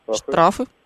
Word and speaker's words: штрафы: А еще у меня вопрос, как правильно, шкафы или шкафы? – Шкафы. – А штраф? – Штрафы? – А, слова похожие, а ударение... – Слова похожие, а ударение разное.